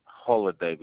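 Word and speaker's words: Holliday: Holiday with two L's. – Spell it for me. – H-O-L-L. It's just like a holiday, just with two L's. – It's just like a who? – Holiday. Just a moment. It's a H-O-L-L-I-D-A-Y.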